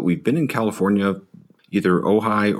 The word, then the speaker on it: Ojai: Is it Ojai, California? – That's right. Yeah, that's always the first question in each podcast is how to pronounce Ojai, and you got it right.